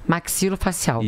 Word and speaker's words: maxilofacial: E a respeito da especialidade, me corrija se a pronúncia estiver incorreta, maxilofacial. Isso.